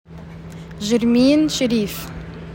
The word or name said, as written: JERMINE SHERIF